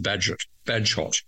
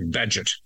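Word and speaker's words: Bagehot: His name is Baggehot, and I thought it was Badgehot. It's actually Badgett.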